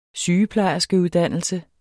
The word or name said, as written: sygeplejerskeuddannelse